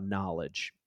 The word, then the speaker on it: knowledge: And now I want to talk about knowledge, because knowledge is a big word. Spelled with a K. There's a silent K at the beginning of it. It's not just N. There's a K.